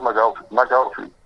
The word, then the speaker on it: McGaughey: Eh, se escribe M-C-G-A-U-G-H-E-Y. Pero. La pronunciación es McAuliffe.